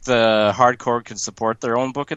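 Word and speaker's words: Hardcorps: I've changed my pronunciation, so I now say Hardcore since everyone says Hardcore. This is one of those like crazy things like that, apparently. – It's pronounced Core. – That's why I now say Core. – So I'm so confused. So is it Corpse? – It's Core. It's Hardcore. – Okay.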